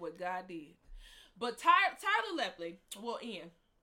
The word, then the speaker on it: Tyler Lepley: Tyler Lepley. I think that's how you say his name. Yes.